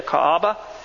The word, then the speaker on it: Kaaba: Is how you say it, the Kaaba.